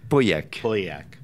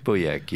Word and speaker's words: Pauillac: The region is, how do you pronounce it? Pauillac? – Pauillac. Pauillac, yes.